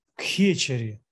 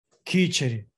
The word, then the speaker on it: кхечари: То есть не кхечари, надо кечари, кечари.